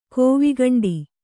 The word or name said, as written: ಕೋವಿಗಂಡಿ